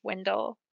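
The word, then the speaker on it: Wyndle: I know I'm wrong about everything, but I say his name is Windel. – I think that's actually how it is. I was just going along with what Dave said. – Yeah, it's Windel.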